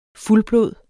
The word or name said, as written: fuldblod